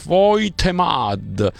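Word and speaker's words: Woltemade: Il tedescone si scrive Voltemad, si legge Voitemad, e Papasar si legge Papsar.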